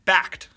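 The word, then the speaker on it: Bakkt: It's B-A-K-K-T. – Yeah. – Yeah. So whatever BAKT, that means. – Yeah. They were going for BAKT as in like the crypto is actually backed by something real. But what BAKT does is it introduces Bitcoin products to the market that can be accessible by institutional investors predominantly.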